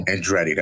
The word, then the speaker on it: Andretti: Andretti, that's how you say it.